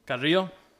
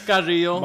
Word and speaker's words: Carrillo: Carrillo. – Oh, I'm trying to sound it all Mexican. – Say it again. – Say it like you normally say. – It's Carrillo. – My name is Ozzy Carrillo.